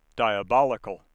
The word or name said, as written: diabolical